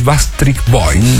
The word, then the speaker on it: Backstreet Boys: Después de contar la anécdota, bien simpática, vamos a traer el tributo oficial en Latinoamérica de los Backstreet Boys.